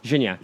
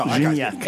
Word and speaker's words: Gignac: How am I supposed to pronounce that? – Gignac. – Gignac? – Oh, Gignac. – It's French. 18.